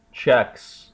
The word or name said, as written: checks